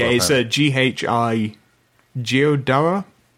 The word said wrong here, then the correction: Ghidorah: Ghidorah. – Ghidorah, yeah.